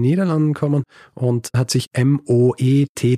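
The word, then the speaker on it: Moët: Eine Sache, die ich nämlich auch falsch gemacht habe in der ursprünglichen Folge, ich habe es Moe ausgesprochen. Ja, ich habe gedacht, ne? Moet. Aber man spricht es Moet aus.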